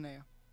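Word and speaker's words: Manaea: It's possible San Diego chooses to start lefty Sean Manessa over right-hander Mike Clevinger. – Sean Manea, I think. – Yeah, sorry. I mispronounced that.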